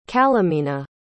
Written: calumina

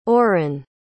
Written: orren

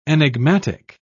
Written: enigmatic

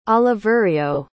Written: Oliverio

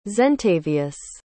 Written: zentavious

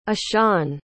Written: ishaan

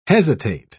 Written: hesitate

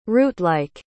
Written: rootlike

